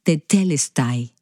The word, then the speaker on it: tetelestai: La palabra la cual he escuchado pronunciada de diferentes maneras, pero la voy a pronunciar de la manera que la he escuchado más a menudo, y es tetelestai.